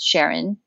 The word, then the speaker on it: Charon: And I sold a feature film last year called Sharon, but it's spelled C H A R O N,